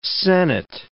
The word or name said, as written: sennet